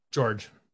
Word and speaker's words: Georges: I do have to ask, how do you pronounce Mr. Niang's first name? – George. – Why is there an S? – Because it's fucking French, motherfucker.